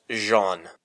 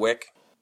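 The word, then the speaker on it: Weick: Yeah, and maybe, you know, actually, I think as I'm looking at this, W-E-I-C-K. I think actually I'm going to go with, I think that's pronounced Jean. – You're so fired. – As you know, I am a native French speaker. – No. Anywho. – Shall we, so are we agreed on Wick? – Yeah, okay.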